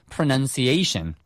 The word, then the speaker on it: pronunciation: P-R-O-N-O-U-N-C-E 동사로 쓰인 거예요. – Pronounce. – 네. 요거 발음 주의하셔야 되겠죠? pronounce고요. 요게 명사형이 되면 pronunciation. – pronunciation. – 하고 발음이 바뀝니다.